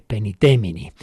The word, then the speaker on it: Paenitemini: Como suelen ser los documentos de la Iglesia, el nombre está en latín, penitemini, haced penitencia, paenitemini, en latín la AE se suele pronunciar como E, penitemini.